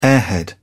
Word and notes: airhead: British English

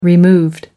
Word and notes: removed: American English

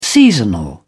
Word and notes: seasonal: British English

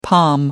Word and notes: palm tree: British English

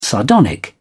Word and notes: sardonic: British English

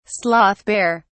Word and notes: sloth bear: British English

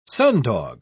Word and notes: sundog: British English

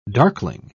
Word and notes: darkling: British English

